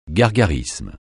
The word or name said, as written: gargarisme